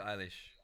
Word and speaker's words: Eilish: Eilish, I bury a friend. It G's me up. This track's good. – Billie Eilish.